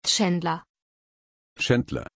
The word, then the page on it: chandler: German